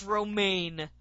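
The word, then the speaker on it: Romijn: That's how it's spelled. How am I supposed to know it's Romaine?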